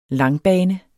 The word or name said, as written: langbane